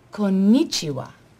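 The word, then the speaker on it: Konnichiwa (こんにちは): Konnichiwa. Please repeat. – Konnichiwa. – Perfect. Good. You say Japanese word like you're Japanese. – Oh, now you're making fun of me. – No. Serious business. Pronunciation very good.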